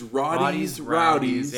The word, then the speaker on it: Roddy's Rowdies: Versus Rowdy's Rowdies. Did I say that right? – No, Rowdy's Rowdies. – Versus Roddy's Rowdies.